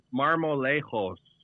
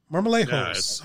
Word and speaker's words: Marmolejos: Marmolejos. – Marmolejos. Yeah, all right. – The J is an H.